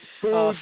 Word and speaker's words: Puig: It's Pooch with a hard G, so it's Pooch.